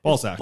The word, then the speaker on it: Balzac: You press out the S. – Yeah, make sure you pronounce that with the Z there. – Balzac.